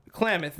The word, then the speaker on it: Klamath: Klamath. That's how they pronounce it, was Klamath.